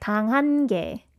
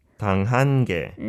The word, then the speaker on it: 당한 게: Yeah, I think this 당한 게 would be a little bit difficult for you, but it should be like 당한 게. – 당한 게.